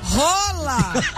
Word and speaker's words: Haaland: Rola. – Que isso? – É, gente, é a pronúncia do nome dele. Haaland. É Rola.